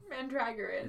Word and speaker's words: Mandragoran: Lan, I don't know how to pronounce his last name. – Mandragoran. His full name is Alan Mandragoran.